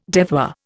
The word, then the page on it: devoir: Indian